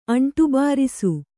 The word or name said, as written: ಅಂಟುಬಾರಿಸು